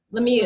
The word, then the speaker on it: Lemieux: Lemieux. Lemieux. Yeah, it's, French. It's like Creole, right? – It's French, yeah.